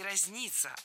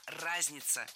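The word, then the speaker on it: разнятся: Нет, не разница, а разница. Представляете, в этом слове ударение должно падать на первый слог.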